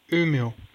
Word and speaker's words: Umeå: Umeå.